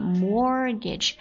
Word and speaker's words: mortgage: Mortgage 注意一下它有一个字母是不发音的 T 不发音来拼一下 M-O-R-T-G-A-G-E